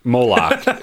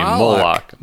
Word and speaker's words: Moloch: Moloch. You have been mispronouncing his name. Moloch. Moloch. – Moloch. – Moloch. – It is Moloch.